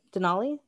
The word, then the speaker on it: Denali: Is that how you say her name? Denali? Is that right? – Yes, I believe that is right.